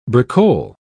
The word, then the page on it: bricole: British English